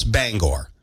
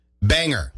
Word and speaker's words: Bangor: And the reason Bangor made the list is because of the way out-of-staters pronounce Bangor. Banger.